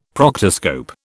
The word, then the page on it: proctoscope: British English